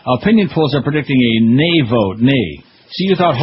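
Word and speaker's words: nee: But in Dutch, it's nay is how you say no.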